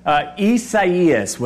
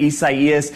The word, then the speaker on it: Isaias: Isaias, which I believe is another way of saying Isaiah. So again, Isaias.